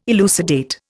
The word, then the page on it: elucidate: Indian